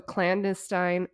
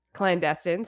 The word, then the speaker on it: clandestine: Also, clandestine marriages. Which I assume that means like without telling your parents. – Clandestine, sorry.